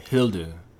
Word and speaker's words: Hilde: And I thought that her name was slightly odd because it sounded like Hilda, but everyone was pronouncing it in this very kind of like almost German way, Hilde.